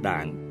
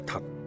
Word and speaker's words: đạn: Thạch đúng ra phải đọc là đạn, khi chỉ đơn vị đo lường, nhưng ta vẫn quen đọc là thạch.